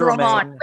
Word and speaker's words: Romain: His name's Romaine. – Wait, by the way, most of my company is French. So I have a French coworker named Romain. It's pronounced Romain.